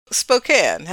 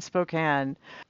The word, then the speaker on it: Spokane: Spokane has a silent E.